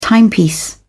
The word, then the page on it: timepiece: British English